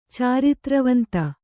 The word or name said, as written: ಚಾರಿತ್ರವಂತ